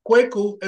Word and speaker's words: Kweku: But that same K, like, you know, in the first, my first name, Kweku, at the beginning of the word is pronounced K, like we normally, K.